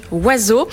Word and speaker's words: Oisx: Alors, je précise que ça s'écrit avec les lettres O-I-S-X, mais que ça se prononce donc Oiseau.